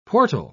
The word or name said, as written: portal